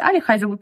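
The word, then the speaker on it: Ali Hazelwood: Я, кстати, не знаю, почему они говорят Али Хайзелвуд, потому что читается Эли Хайзелвуд. Ну, меня всегда исправляют, говорят то, что это Али. Я такая, я уже привыкла к Эли.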